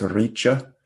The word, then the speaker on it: richa: That means thy kingdom come, but they say Tobakuma, which means come, vin, thy, your, richa, which is spelled like rice, but it's pronounced richa.